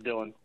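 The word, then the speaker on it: Dylan: It's D-Y-L-A-N, it's Dillian.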